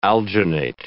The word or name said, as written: alginate